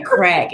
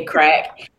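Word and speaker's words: craic: So they're saying crack, and it's spelled C-R-A-I-C, I think, but they say crack.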